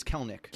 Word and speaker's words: Kelenic: Are we saying it right? Is it Kelenic? – I think it's Kelenic. – Kelenic. – Yeah. – So you don't really pronounce the second E, but Kelenic.